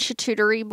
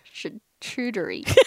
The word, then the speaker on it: charcuterie: Did I say that right? – Nope. – try again. – Charcuterie. – Nope. I'm not even going to try it because I know I'll butcher it. – Charcuterie. – There you go.